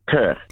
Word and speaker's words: Kirk: Kurt.